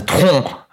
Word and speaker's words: Troyes: How do you pronounce T-R-O-Y-E-S? – Say it again. – T-R-O-Y-E-S. – Troyes. – I would guess they're a French team. So, Troyes. Okay, let's call them Troyes.